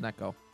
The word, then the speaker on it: Necco: How do you pronounce it? – I think it's Necco.